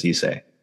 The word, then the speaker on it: Issei: You know, Issei is always spelled with E E I, yeah.